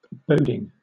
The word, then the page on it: boding: British English